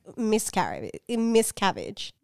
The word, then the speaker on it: Miscavige: Miskovich? – David Miskovich. – He's the Miscavige. – I'm going to pronounce it Miskovich. – Miscavige.